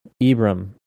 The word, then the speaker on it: Ibram: Ibrahim Kendi? – Is it Ibrahim? – I can't remember how to say his first name. Ibram X. Kendi.